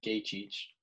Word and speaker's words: Gaethje: I'm assuming that Gaethje – did I say his name right? – Yes. – Okay.